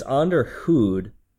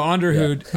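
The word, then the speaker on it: Onderhoud: So it's Onderhund, which is, it's Dutch for maintenance. – Interesting. Oh, then that would make sense. That's pronunciation. That sounds more Dutch. So Onderhund, yeah.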